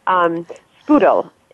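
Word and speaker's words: Sprudel: Spudel is how you pronounce club soda.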